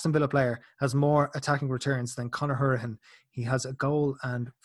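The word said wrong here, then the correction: Hourihane: I actually realize I've already pronounced his name wrong. He likes to be pronounced uh, Howrahan, not Hurahan.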